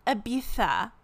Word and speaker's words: Ibiza: That's like the Z is pronounced with the th sound. The th. Ibiza.